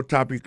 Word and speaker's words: topic: Alors, mot topic là, qui signifie sujet, ça veut dire accent tonique là tombé sous premier syllabe là.